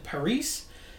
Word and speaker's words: Parise: Jeffrey Vincent Parise. I think I'm saying that right.